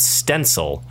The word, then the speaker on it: Stencyl: I am going to recommend a software called Stencil. It's spelled Stencyl.